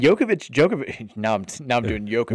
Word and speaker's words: Djokovic: Djokovic is three syllables.